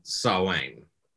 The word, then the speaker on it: Samhain: And eh, so just so we're clear, like that's not spelled Samhain. – Spelled it's spelled Sam Hain. Correct. – Is why it's confusing.